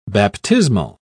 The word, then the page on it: baptismal: British English